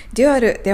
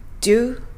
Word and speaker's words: dual: 最初、デュアルの発音です。デュアルではなく、デュオみたいなカタカナで発音していただくといいと思います。デュ l こんな感じ。